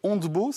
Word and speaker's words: Hondebo: Vous êtes la fondatrice de Hondebo, c'est comme ça qu'on prononce Absolument.